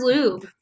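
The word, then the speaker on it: Ljub: But it is pronounced Lube. – It is pronounced Lube.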